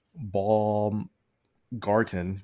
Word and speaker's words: Baumgarten: I apologize for butchering his name. Harold Baum Garten, Baumgarten.